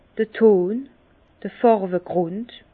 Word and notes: d'r Ton, d'r Fàrwegrùnd: Bas-Rhin; Herrlisheim

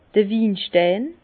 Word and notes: d'r Winstein: Bas Rhin; Herrlisheim

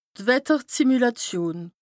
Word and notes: d' Wettersimülàtion: Bas Rhin; Strasbourg

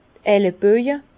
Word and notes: d' Elleboje: Bas-Rhin; Herrlisheim

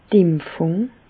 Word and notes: d' Impfùng: Bas-Rhin; Reichshoffen

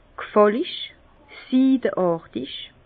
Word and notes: g' fàllig, siideàrtig: Bas-Rhin; Herrlisheim

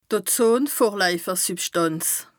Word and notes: d' Ozonvorlaifersübschtànz: Bas Rhin

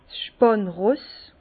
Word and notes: 's spànn Ross: Bas-Rhin; Herrlisheim

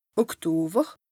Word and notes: Oktower: Bas-Rhin; Strasbourg

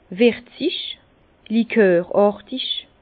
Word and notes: wirzig, likoràrtig: Bas-Rhin; Herrlisheim

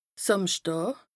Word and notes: Sàmschdàà: Bas-Rhin; Strasbourg

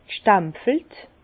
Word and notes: g' stempfelt: Bas-Rhin; Herrlisheim

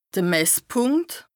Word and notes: de Messpùnkt: Bas Rhin

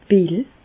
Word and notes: d' Pill: Bas-Rhin; Reichshoffen

